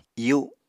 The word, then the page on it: iyu: apatani